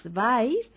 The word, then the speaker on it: vais: Vosotros, vosotras, vais, escriba, vais. – الضمير vosotros, vosotras, vais. – Recuerde que se escriben con V. – لنحاول أو تذكر أن هذا الفعل يكتب بالحرف V وينطق باء.